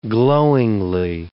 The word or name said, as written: glowingly